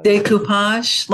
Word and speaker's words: découpage: So Alice walks into thinking she's going to, how do you pronounce it? Decoupage? – Découpage, like pasting things on campuses.